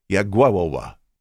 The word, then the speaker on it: Jagiełło: By the way, in case you want to look him up, the spelling on his last name is J-A-G-I-E-L-L-O, pronounced Jagiełło.